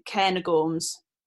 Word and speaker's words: Cairngorms: I had my friend basically said say it like Cair with an N. So Cairngorms, I think is how you say it.